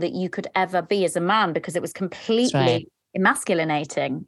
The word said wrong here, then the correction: emasculating: Is that right? Emasculinating? I don't know if I'm saying that right. – Emasculating, yeah. – Emasculating.